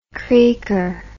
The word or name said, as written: creaker